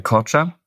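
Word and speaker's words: Korça: Da habe ich jetzt hier, keine Ahnung, ob das richtig ausgesprochen ist, Korka, Dada und Ochridsee. – Korca, ist es tatsächlich? Korca.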